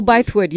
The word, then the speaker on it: Bythewood: Gina Bethwood. – Bythewood, yeah, yeah.